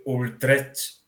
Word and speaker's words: Utrecht: Utrecht.